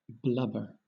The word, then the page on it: blubber: British English